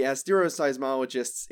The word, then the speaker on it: asteroseismologists: The asteroseismologists in my department would be disappointed with me if I pronounced it any other way.